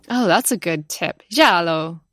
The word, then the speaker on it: giallo: Well, we brought up the word giallo a couple times, and Cam, you're actually the one who taught me how to say the word giallo. You say it like you're saying ciao, giallo.